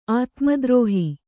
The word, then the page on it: ಆತ್ಮದ್ರೋಹ: ātmadrōha; kannada